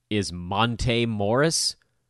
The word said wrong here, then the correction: Monte: Sorry, it's Monte. Get that right. Got to put the accent on the right syllable.